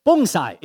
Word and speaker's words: bonsai: Okay, now, bonsai is actually Japanese pronunciation. In Chinese, we call it bonsai, very close.